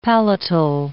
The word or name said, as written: palatal